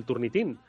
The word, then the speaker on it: Turnitin: Sí, bien, de entrada yo eh, lo españolizaría y lo llamaría turnitin porque bien, es una cuestión de, acento, básicamente, ¿no? Los americanos ya dicen más turnitin y bueno,